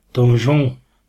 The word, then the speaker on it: Donjon: In French, the title is Donjon. – Donjon. – Donjon. It's spelt different.